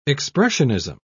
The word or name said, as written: expressionism